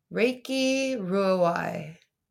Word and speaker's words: Ruawai: R-U-A-W-A-I? – Ruawai. – Ruawai. – Reiki Ruawai. I'll do that again. My drop of the week is Somewhere by Grafix and Reiki Ruawai.